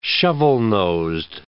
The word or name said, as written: shovel-nosed